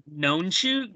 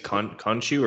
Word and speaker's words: Khonshu: So until we hear it, I'm going to say Nonshu. – It's spelled Khonshu. So I think it's Khonshu or Khonshu.